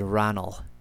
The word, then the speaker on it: Ranald: Now, if you notice that as you listen to this podcast, you'll notice that I often, like right there, I did not say Ranald. I said Ranald. I'm trying to maintain closer to the original Gaelic pronunciation. You don't have a D in Ranald. Now, Ranald does come from an old Norse name, something like Ragnvaldr. I'm probably just completely butchering that, but there's a D in the original Norse name, but they don't use it in Gaelic. It's just R-A-G-H-N-A-L-L.